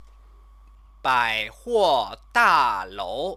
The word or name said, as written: baihuodalou